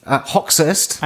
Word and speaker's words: Hoxhaist: Hoxhaist. – And how are you spelling that? – Yeah. – H-O-X-H-A-I-S-T. – Hoxhaist. – Hoxhaist, I think is how you say it. So it's an Albanian name.